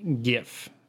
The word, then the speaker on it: GIF: So most people take the hard G sound like in the word guess from the word graphics in graphics interchange format and pronounce the word GIF.